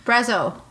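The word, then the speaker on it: Brazeau: So I'm not going to try to pronounce Jay's last name, who played Harlan. – Brezzo. – Brezzo? – Yeah, I'm going to go with Brezzo.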